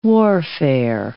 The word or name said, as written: warfare